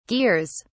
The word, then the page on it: gears: British English